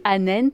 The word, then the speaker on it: Anent: Donc Anent, c'est comme ça qu'on le prononcera, sous-titré Nouvelles des Indiens Jivaro d'Alessandro Pignocchi, préfacé par Philippe Descola.